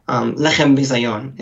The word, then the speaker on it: lechem bizayon: Lechem bizayon is how you say it in Aramaic.